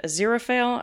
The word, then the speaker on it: Aziraphale: Well, no, because it talks about how, you know, Crowley and Aziraphale, I think is how you pronounce his name.